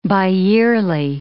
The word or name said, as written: biyearly